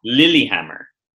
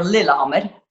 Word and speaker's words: Lillehammer: So you have a little yoga enclave where you are in Lillehammer. Am I pronouncing that correctly? – Lillehammer. Or Lillehammer.